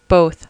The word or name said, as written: both